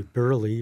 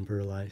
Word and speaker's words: Burleigh: Like Burleigh is really Burley rather than Burleigh.